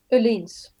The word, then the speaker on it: Öhlins: So that's how do you say that in Swedish? – Olin's. – Olin's. – This is the Swedish pronunciation.